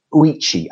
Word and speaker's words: Ouchi: I'm not sure how to pronounce his surname. I think it's Ouchi, O-U-C-H-I.